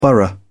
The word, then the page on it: borough: British English